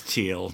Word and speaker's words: Thiel: however you pronounce his last name. – It's Thiel.